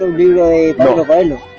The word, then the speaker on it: Coelho: De hecho, se pronuncia Coelho.